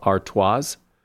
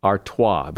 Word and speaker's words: Artois: A lot of people pronounce that last S as Stella Artois, but it's Stella Artois. The last S is silent.